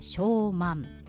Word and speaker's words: 小満: 小さく満ちると書いて小満です。